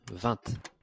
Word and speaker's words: vingt: De stavar ju så här, LXX, och jag tror att det då på franska ska uttalas någonting i stil med Levang eller någonting. – Mm-hmm. – V-I-N-G-T. – Vant.